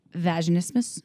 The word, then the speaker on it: vaginismus: And we were trying to decide on the pronunciation because my series has an Australian accent, and I believe it's vaginismus. – Vaginismus, yes, exactly.